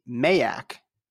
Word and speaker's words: MAAC: I've had it with a confusion in pronunciation between the Mac, and the Mac, M-A-C-K. Mac, M-A-C, pronounce as you've been pronouncing it. Mac, M-A-A-C, Mayak. There's two A's. You both get A sounds with this, long and short.